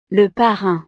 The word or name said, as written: le parrain